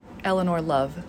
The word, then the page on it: Eleanor Love: Elenore Love